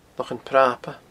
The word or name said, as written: Lochan Prapa